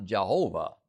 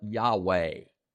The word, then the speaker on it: Yahweh: If it was, most scholars today believe it was pronounced not Jehovah, but Yahweh. Yahweh would have been how they pronounced those four consonants.